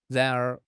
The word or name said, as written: ran2 er2